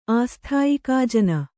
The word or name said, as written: ಆಸ್ಥಾಯಿಕಾಜನ